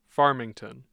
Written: Farmington

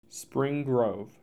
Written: Spring Grove

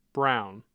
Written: brown

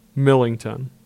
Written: Millington

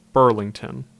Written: Burlington

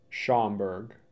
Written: Schaumburg

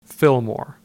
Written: Fillmore

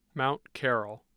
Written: mount carroll

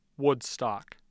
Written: Woodstock

